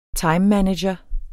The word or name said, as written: timemanager